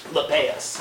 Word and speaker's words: Lappeus: Lepaeus? – Lepaeus. – How do we spell that? – L-A-P-P-E-U-S.